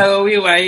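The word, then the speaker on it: Ken Saro-Wiwa: Ken Saro Wiwa, yes.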